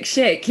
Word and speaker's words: Schick: Schick, have I said his name right? – Patrick Schick, yeah, Patrick he's been a revelation.